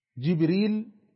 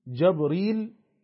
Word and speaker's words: جبريل: النوع الثالث من أنواع الاختلاف اختلاف في نطق الكلمة، جبريل جبريل، جبريل بكسر الجيم، جبريل بفتح الجيم، جبرائيل جبرائيل.